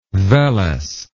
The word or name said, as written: Wellesz